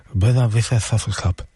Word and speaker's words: Buena Vista Social Club: Ich hätte das nicht ordentlich ausgesprochen, weil die werden ja wohl aus Kuba, das müsste man spanischer aussprechen. Also bitte, Buena Vista Social Club. Buena Vista Social Club. So ungefähr.